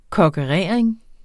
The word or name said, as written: kokkerering